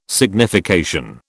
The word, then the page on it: signification: British English